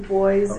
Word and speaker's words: Boyes: My name is Kathy Boyes, and I'm the pastor of the church.